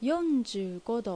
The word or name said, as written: Yonjuugo